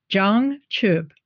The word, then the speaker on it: jang chub: And I think if we look at the Tibetan word for enlightenment, it's a jang chub. Jang is spelled J-A-N-G. And the second word is chub, C-H-U-B.